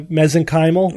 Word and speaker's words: mesenchymal: When in doubt, the accent goes on the semi-penultimate syllable, second to second to last syllable. So mesenchymal.